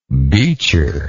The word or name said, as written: beacher